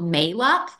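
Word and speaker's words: Mayluck: But the brand that Dr. Mercola lists on his website is called Malux, and that's $50 on Amazon. – How do you spell it? – It's M as in Mary, A-Y-L-U-C-K. May like the month and luck, like good luck.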